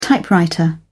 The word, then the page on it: typewriter: British English